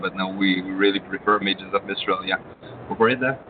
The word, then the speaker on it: Mages of Mistralia: And well, first of all, how do you pronounce the game? Mages of Mistralia? – Mages of Mistralia. – Okay. – Like Images of Australia, but you have to change some letter into that.